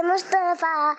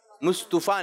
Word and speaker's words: مصطفیٰ: مصطفیٰ نہیں، صحیح ادائیگی کیا ہے، صحیح تلفظ کیا ہے؟ غلام مصطفیٰ، مصطفیٰ،